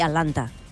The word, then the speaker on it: Atlanta: hem de dir Atlanta. Una altra cosa és que, efectivament, com que vull dir, la T la pronuncien tan poc, acaben no pronunciant-la, però en un llenguatge molt informal.